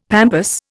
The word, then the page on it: pampas: Indian